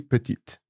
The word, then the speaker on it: petite: pronounce a final E, but other than that, it's just the T that's pronounced here. Yeah. So petit, petite. – Yeah. The other situation, you may hear that final E pronounced is in a song.